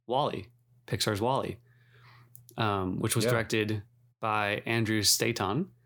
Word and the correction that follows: Stanton: Staton. – Is that really how you pronounce it? Staton? – Stanton? I guess it's Stanton.